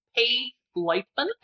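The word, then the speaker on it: Paige Leitman: And you can find me on Twitter at Paige Leifman, and that's spelled PAIGELEIT like tango, MAN.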